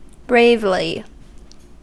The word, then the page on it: bravely: /ˈbreɪvli/; American English